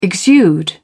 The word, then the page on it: exude: British English